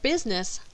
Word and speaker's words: business: The word business looks like it has three syllables, but when spoken, the I in the middle gets skipped and it is pronounced with just two syllables.